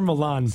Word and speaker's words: Millán: Es como César Millán, es César Milán.